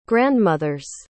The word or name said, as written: grandmother's